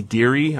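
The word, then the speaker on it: Derie: His name's Bobby Deary. I think that's how you're supposed to pronounce his name. D-E-R-I-E, I think.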